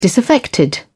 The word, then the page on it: disaffected: British English